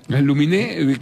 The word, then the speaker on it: Aluminé: ¿Pero Aluminé? – Aluminé, que termina con acento en la E, no, le pone alum...